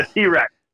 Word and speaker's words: TRX: The T-Rex.